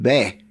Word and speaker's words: Bex: We got off the train, you probably remember from your days in Switzerland, we got off the little town of Bex, they pronounce it, B-E-X, for a couple of days of recreation into the Alps.